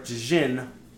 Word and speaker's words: djinn: a spirit or a djinn possess, like sealed within it. – It's like Django Unchained. The D is silent. It just says djinn.